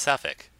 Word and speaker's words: Southwark: It's not Suff. It's not Suffolk. It's Suffolk. Um, but ah, pronounced, oh, okay. but spelt Southwark.